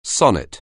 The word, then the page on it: sonnet: anglais